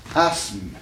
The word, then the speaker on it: asthme: À tel point que dans les textes, vous trouvez, au lieu de I-S-M-E, vous trouvez I-Z-M-E. Voyez-vous? Prenons un mot qui indique une maladie respiratoire. – Asthme. – Hein? Donc, il l'a aussi, n'est-ce pas? S-M, n'est-ce pas? Faisons, écartons le TH de l'orthographe, n'est-ce pas? Qui ne se prononce pas.